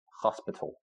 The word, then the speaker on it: hospital: Colegio, hospital. Same spelling, but the H is silent.